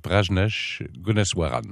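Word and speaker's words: Prajnesh Gunneswaran: Parnesh Gunneswaran.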